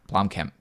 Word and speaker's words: Blomkamp: Blomkamp, excuse me.